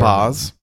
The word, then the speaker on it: Baz: Baz.